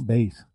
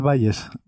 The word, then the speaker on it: Bayes: Que no es como las aspirinas, no es Valles.